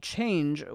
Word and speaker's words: Chanje: In less pretty but more realistic news, a California company called Chanje, which is spelled like change, but with a J instead of a G because it's from California, has announced a new electric car.